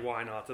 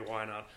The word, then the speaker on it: Whynot: And they you know, they took one crack at pronouncing my name and they were like, yeah, that's not going to work. – Oh, wow. – And so they were like, well, we'll just call it, because it sounded like Whynot to them.